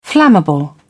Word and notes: flammable: British English

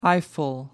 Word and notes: eyeful: American English

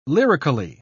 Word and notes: lyrically: British English

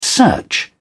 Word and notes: search: British English

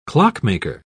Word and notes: clockmaker: British English

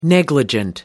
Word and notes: negligent: American English

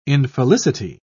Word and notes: infelicity: British English

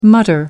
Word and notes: mutter: American English

